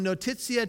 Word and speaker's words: notitia: notitia. I'll spell it for the note takers. N-O-T-I-T-I-A.